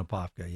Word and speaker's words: Apopka: Born and raised in Apopka, yes. – Oh, that is always fun to do, because you said it right, Mayor.